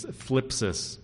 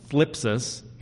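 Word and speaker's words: thlipsis: Some of you have heard that funny Greek word. It's phlipsis. Okay? It's a little difficult to pronounce, especially if you have a lisp. But it's called phlipsis. Okay?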